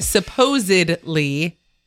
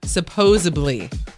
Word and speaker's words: supposedly: It's supposedly, not supposably.